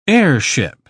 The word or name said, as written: airship